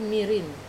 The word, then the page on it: marin: Wamesa